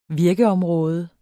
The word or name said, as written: virkeområde